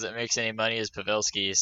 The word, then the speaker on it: Pavelski: So, Gerionov and Pavelski, I have to start saying it right because Dad said, oh, you can't say Pavelski anymore. – Pavelski. – It's Pavelski. Whatever.